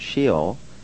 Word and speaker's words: Sheol: which is pronounced Sheol, and it means...